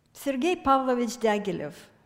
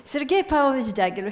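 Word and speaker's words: Sergei Pavlovich Diaghilev (Сергей Павлович Дягилев): So, Sergei Pavlovich Diaghilev. One thing is in my favor is that I'm Russian, so I can pronounce it correctly. Sergei Pavlovich Diaghilev, here are his dates.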